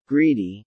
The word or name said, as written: greedy